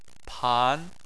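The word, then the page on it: pan: tone 2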